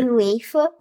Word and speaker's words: UEFA: Is it pronounced UEFA? Is that how people say it? – UEFA. – UEFA? Okay, UEFA.